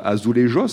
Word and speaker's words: azulejos: Alors on peut prononcer des deux façons, euh, Azulejos si on le prononce euh, à la manière portugaise ou Azuleros si on parle espagnol, mais là ce sont des, des Azulejos portugais.